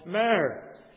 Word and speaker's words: myrrh: And among the spices was the one called myrrh. M-Y-R-R-H. Myrrh. Sometimes it's spelled with only one R.